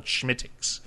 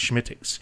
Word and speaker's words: Smithwick's: It looks like Smithwick's, but it's called Schmittix. Uh-huh. It's pronounced Schmittix.